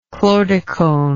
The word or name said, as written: chlordecone